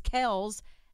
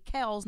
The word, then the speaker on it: Kelce: On the New Heights podcast, I guess Ed Kelce said that it was always Kelce and everyone mispronounced it for years, and it's really Kels, not Kelce.